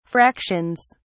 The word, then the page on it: fractions: British English